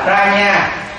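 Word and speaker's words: prajñā: Người ta là Prajna chứ không phải là ban nhược.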